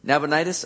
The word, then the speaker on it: Nabonidus: That is until recent history when the cylinder of Nabonidus, how do you say that? Nabonidus? Am I about right? Nabonidus? Okay. There you go.